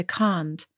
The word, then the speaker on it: can't: It's about a long vocal or short vocal. The see you next Tuesday word has a short vocal, and can't, you say can't, and then you are already out of it because you cannot say it doesn't come to the see you next Tuesday word.